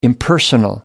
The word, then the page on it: impersonal: American English